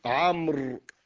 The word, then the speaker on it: عمرو: كذلك كلمة عمرو، هذه الواو تكتب ولا تنطق حتى يفرق بينها وبين عمر،